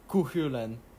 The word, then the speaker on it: Cú Chulainn: Because he doesn't have survivability like Kukulin. Or Kuhulan. Apparently, that's how you pronounce it.